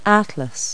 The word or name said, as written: atlas